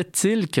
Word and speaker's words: Thielcke: Il s'appelle Henri-Daniel Tilke, T-H-I-E-L-C-K-E. Ça se prononce apparemment en français Tilke, même si c'est d'origine germanique.